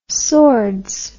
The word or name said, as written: sordes